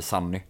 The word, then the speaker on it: Sonny: Men när Sonny så att säga uttalas på svenska, då blir det Sunny. Mm. Eh, med ett A.